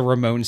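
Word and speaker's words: Ramones: And you said it correctly. It's the Ramone sound.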